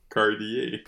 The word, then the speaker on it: Cartier: Ah, ça, ça se prononce Cartier.